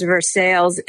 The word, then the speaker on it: Versailles: The reason that people say Versailles rather than Versailles is the early settlers of this region were Scots Irish, and they have a habit of pronouncing the emphasizing the second syllable as opposed to the first. So that's how you get Versailles.